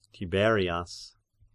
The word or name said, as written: Tiberias